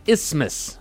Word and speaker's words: Isthmus: The correct way to say it is Isthmus.